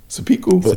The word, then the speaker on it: seppuku: If you serious about this shit, then I'm going to commit seppuku. Did I say that right? Seppuku. – Seppuku.